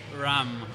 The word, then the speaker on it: Ram: Ram, R-A-M.